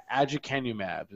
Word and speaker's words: Aducanumab: Aducanumab is what.